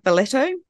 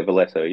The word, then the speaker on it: Valetto: Valetto? – It is. Yeah, Valetto. Yeah. And it's funny, the capital of Malta is Valletta. So it's just one letter difference, Valetto.